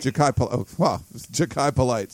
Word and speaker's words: Jakai Polite: With the recent offers to defensive linemen like Boss Tagaloa and Jackie Polite? Is that how you say his name? – Jakai Polite.